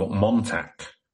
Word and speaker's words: Montauk: The Montauk. – Again, it's going to be another one where I pronounced this wrong, but M-O-N-T-A-U-K.